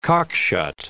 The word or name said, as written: cockshut